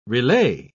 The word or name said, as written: relay